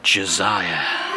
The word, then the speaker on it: Josiah: Josiah. This ancient Hebrew name means Yahweh supports, and Josiah was the king of Judea. It's more traditional to pronounce the J with an H sound, like Hosea. But in English, you could pronounce the name with the J sound and opt for Joe or Joe as a nickname.